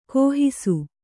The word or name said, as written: ಗಾಹಿಸು